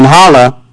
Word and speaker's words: Jala: In Hala, if you look back on the map, Jala is spelled J-A-L-A. In Spanish, the J makes an H sound.